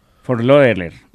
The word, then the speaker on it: Fort Lauderdale: Fort Lauderdale.